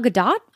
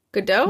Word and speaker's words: Gadot: Godot? – No, it's not Godot. Gal's she's Israeli.